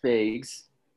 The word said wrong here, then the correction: Fage: I don't know if I'm pronouncing the last name right. – I think it's Fage, like yogurt.